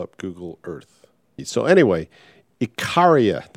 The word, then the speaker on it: Icaria: So anyway, we're sailing across to Icarus, or Icaria, I should say.